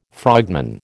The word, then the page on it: frogman: American English